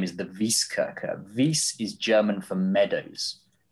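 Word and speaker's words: Wieskirche: Is that Vieskirch? Is that how you spell it? – This is Vieskirche.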